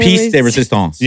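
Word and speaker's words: pièce de résistance: The piece de resistance.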